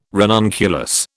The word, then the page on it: ranunculus: British English